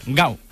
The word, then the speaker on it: Gal: sino Gal, que en portugués de Brasil se pronuncia igual, gau, con U final.